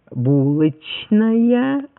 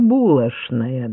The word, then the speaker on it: булочная: Ведь в Петербурге говорили «булочная», а в Москве «булочная», да? То есть в Петербурге «чна» произносили как «чна», а в Москве «чна» как «шна».